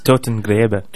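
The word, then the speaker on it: Totengräber: I'm going to fail to pronounce this track name. Totengraber, is that correct? – It's Totengraber.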